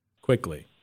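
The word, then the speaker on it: Quickley: And is it Quick? How do you say the other guy's Quickly. – Name? Quickly. – Quickly. – Quickly. Thank you.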